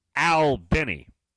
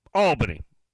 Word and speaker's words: Albany: I assume they call it Albany because you got to remember, in Georgia, it's pronounced Albany. Or Albany. Yeah. Albany, like A-L-L and then dash B-E-N-N-Y. Up in New York, it's Albany, where Charlie's from.